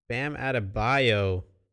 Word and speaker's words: Bam Adebayo: Bam Adebayo.